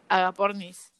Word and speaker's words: Agapornis: Agro, eh, agropornis, ¿cómo es? – agapornis.